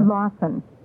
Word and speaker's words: Lausen: It's spelled Lausen. So it's the Scandinavian Lawson.